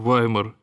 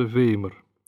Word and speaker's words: Веймар: и поступил на службу в Ваймар, или, как принято произносить по-русски название этого города, Веймар, столицу Заксен-Ваймарского княжества, на весьма престижную должность придворного органиста и камер-музыканта герцога Вильгельма Эрнста.